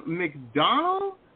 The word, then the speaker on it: McDonough: Next up, we have Sean McDonald. I believe I'm pronouncing that right. – That was wrong. That was not bad. That was not bad. Usually people get it worse. Sean McDonald. That was pretty good.